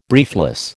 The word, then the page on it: briefless: American English